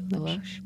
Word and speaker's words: Baloche: Paul Baloche. – Oh, yeah. I think that's right. – Baloche.